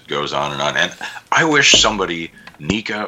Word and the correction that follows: NECA: or I think it's NECA, sorry, I pronounced it wrong,